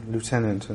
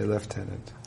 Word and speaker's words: lieutenant: And why do we say lieutenant and they say lieutenant? – Well, a lot of words had more than one pronunciation in quite early times. And what frequently happened in those cases is that one of the pronunciations would survive in Britain and the other would survive in America.